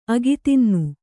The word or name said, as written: ಅಗಿತಿನ್ನು